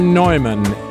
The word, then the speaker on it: Neumann: And today is the memorial of St. John Neumann, or Neumann in his native pronunciation.